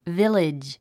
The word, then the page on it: village: vílidʒ; ビレッジ